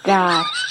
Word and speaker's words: chingar: Chingar. – Chingar. – C-H-I-N-G-A-R. – and you roll your R.